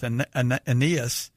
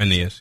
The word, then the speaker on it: Aeneas: I think what's interesting is, you know, what happens with Aeneas. So how do you pronounce Aeneas? – Yeah.